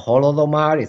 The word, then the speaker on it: Holodomor: We can talk about Holodomor.